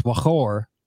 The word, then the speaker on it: Mawcore: And actually, Mawcore, what you're saying is a Hebrew word, and it's more likely pronounced Mawcore in Hebrew. – Yeah, than it is what we do. We Americanize it here, and it means wellspring and the underneath of the well.